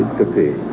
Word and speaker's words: ornithoscopy: Ornithoscopy.